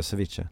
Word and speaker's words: ceviche: ceviche. – Precis, det är så man uttalar det, ceviche.